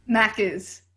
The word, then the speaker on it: Macca's: Macca's. – Macca's? – Macca's. – Yeah, M-A-C-C-A-S.